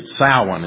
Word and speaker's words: Samhain: It's spelled S-A-M-H-A-I-N, but it's not pronounced that way. It's Samhain is what it's pronounced.